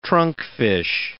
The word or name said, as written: trunkfish